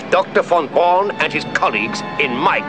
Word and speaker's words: Von Braun: Von Braun is.